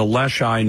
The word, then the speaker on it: Leschi: What happened in the Leschi neighborhood of Seattle? – Sure.